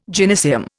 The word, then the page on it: gynaeceum: Indian